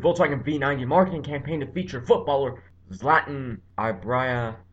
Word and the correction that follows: Ibrahimovic: I'm so sorry, man. His last name is Ibrahimovic. That's how it's spelled.